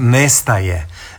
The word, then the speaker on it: nestaje: U standardnom jeziku je upravo ovako, nestaje. I u jednom i u drugom slučaju naglasak je isti. Ovdje naglasak prelazi na proklitiku, odnosno na ne, a tamo je to puni oblik glagola i nemoguće ga je drugačije izgovoriti. Ali moramo onda iz konteksta razumjeti, dakle iz okolnog teksta razumjeti njegovo značenje.